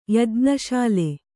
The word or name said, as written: ಯಜ್ಞಶಾಲೆ